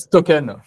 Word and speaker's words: Stokn: Moi, je le euh, prononce Stoken.